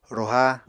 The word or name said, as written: roha